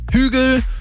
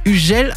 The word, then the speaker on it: Hugel: Wobei ich hier ganz ehrlich sagen muss, die ersten zwei Wochen war ich so ein bisschen mehr damit beschäftigt, herauszufinden, wie man eigentlich den Sänger da ausspricht. Hugel, Hügel, Hugel. Und dann habe ich irgendwann gecheckt, nee, das Ganze ist französisch und da spricht man dann einfach mal Hugel aus.